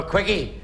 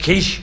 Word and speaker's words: quiche: Is any chance of a quickie? She lifted her hand, she slapped him across the face and she marched off. And his friend, he gave him a dig, he said, I think it's pronounced quiche.